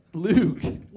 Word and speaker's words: Loog: But then the band got a real manager called Andrew Luge Oldman. And Jones... – Luge. – L-O-O-G.